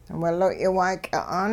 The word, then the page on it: wiloy' waayky'a'aan: hupa